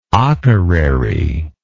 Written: Akureyri

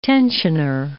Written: tensioner

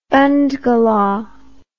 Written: bandgala

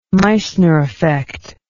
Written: meissner effect

